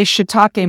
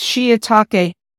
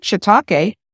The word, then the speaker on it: shiitake: We often say shiitake mushrooms. It's shiitake. I think I learned the proper pronunciation from Ming Tsai, the chef. Ming Tsai, he says shiitake.